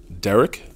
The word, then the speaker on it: Derek: Derek Ageist. First name Derek is spelled the right way, D-E-R-E-K.